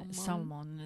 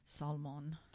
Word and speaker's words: Salman: Salman? Rushdie. – Salman is how he says it. In his very cool voice. – Yep. – Salman.